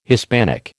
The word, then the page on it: Hispanic: American English